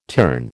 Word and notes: terne: American English

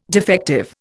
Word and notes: defective: Indian

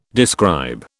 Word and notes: describe: British English

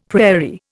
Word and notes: prairie: Indian

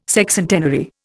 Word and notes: Sexcentenary: Indian

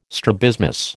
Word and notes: strabismus: American English

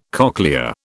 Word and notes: cochlea: British English

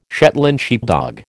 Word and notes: Shetland sheepdog: American English

